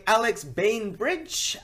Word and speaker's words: Alex Bainbridge: So this week, I am joined by Alex Bainbridge.